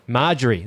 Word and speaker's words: Marjorie: Marjorie, there you go.